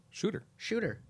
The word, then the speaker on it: Schutter: Be saying that wrong, but I think I am. S-C-H-U-T-T-E-R. – Shooter. – Yeah. – I was hoping it was just a double O, yeah, like boop, boop.